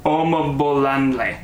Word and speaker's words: Omobolanle: Omobolalele. No, that's not it. Omobolanle. There we go. There's no T in there.